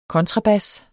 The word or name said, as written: kontrabas